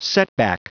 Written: setback

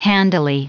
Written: handily

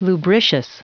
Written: lubricious

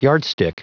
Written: yardstick

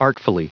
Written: artfully

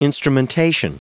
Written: instrumentation

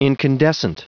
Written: incandescent

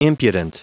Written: impudent

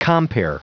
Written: compere

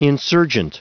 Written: insurgent

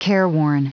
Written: careworn

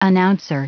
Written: announcer

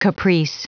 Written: caprice

